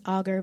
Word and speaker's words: Alger: And when we come to recognize that there's things like Fanny Alger, that I think it's pronounced Auger because I listened to your polygamy and I heard the guest who was from the family. So it's Fanny Auger.